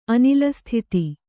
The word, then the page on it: ಅನಿಲಸ್ಥಿತಿ: anilastithi